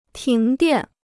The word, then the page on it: 停电: tíng diàn